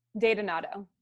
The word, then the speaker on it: DeDonato: And is it Donato? – Datanato. – Datanato. – Uh-huh. – All right. Let me make a little note so I don't butcher it. You can Datanato. Okay, perfect.